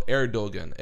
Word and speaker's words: Erdogan: So he's like, why don't you just pronounce it instead of like the ER, pronounce it air. So Erdogan, Eddie Erdogan.